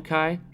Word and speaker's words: Kai: Is that how you pronounce the last name, Kai? – Yes.